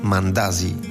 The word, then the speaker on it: Mandazi: Ich wollte unbedingt etwas Süßes Kenianisches probieren, und du hast gesagt, wir machen Mandazi. – Genau, das ist richtig gut ausgesprochen.